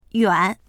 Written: yuan3

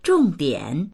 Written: zhong4dian3

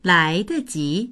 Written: lai2deji2

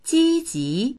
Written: ji1ji2

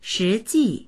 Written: shi2ji4

